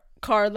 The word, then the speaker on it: Kerr: But like, Deborah Carr is pronounced Carr? Carr. – Carr like star, yeah.